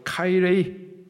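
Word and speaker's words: 카이레이 (χαίρειν): 자, 그런데 오늘 본문에서 이런 이단들에 대해서 인사도 하지 말라 할 때의 인사, 헬라식 발음으로 카이레이, 이런 의미입니다.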